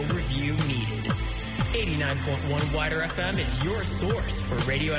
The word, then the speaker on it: WIDR: It's actually pronounced 89.1 Wider FM. – That's what I say. Wider FM.